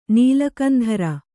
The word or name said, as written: ನೀಲಕಂಧರ